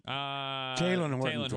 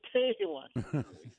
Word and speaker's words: Talen: You know, I mean, the freshman, Talese Horton Tucker. I always have to stop and think if I got his name right. – Talen Horton Tucker. – Yeah. Talen. Every time I get some part of that wrong, and I'm sorry.